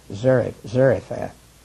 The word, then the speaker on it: Zarephath: And it looks like Zarephath, but I think the pronunciation should be more clearly Zarephath, which is a city near Sidon in Lebanon.